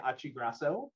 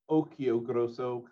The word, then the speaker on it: Ochiogrosso: So Guy Ochiogrosso is how I pronounce it. If we were in Italy, it would be Ochiogrosso, kind of like Pinocchio.